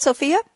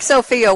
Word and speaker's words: Sofia: And Frank, am I pronouncing your name right, Sofia? – Sofia, that is correct. – Okay, I'm hitting it pretty hard here. Frank, Sofia, what are you drinking today?